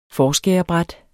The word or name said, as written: forskærerbræt